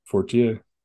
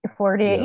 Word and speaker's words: Fortier: Michael Fortier. Is that how you say his name? – Oh, right. – Fortier. – Fortier. Yeah. Yeah.